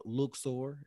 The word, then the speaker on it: Luxor: If you like history, like culture, you definitely have to go to Luxor, if I'm pronouncing that correctly or not, I don't know. – Yes.